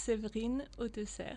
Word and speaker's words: Severine Autesserre: It was perfect, but it's Severine Autesserre.